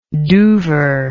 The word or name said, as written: doover